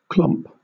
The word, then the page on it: clomp: British English